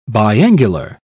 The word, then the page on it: biangular: British English